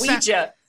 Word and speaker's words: Ouija: He picks up a Ouija board and he pronounces that word O-U-E-J-A, basically. Like he's Ouija. – Yeah, he sounds out every letter and it's very strange.